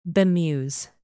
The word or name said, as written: bemuse